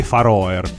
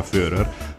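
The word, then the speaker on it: Føroyar: Lei invece di fatto è danese, perché le isole Fører, pur essendo molto vicine, le cosiddette Faroer, però poi si pronuncia Fører, pur essendo molto più vicine all'Islanda che alla Danimarca, fanno parte, legislativamente parlando, della Danimarca.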